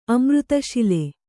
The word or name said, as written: ಅಮೃತಶಿಲೆ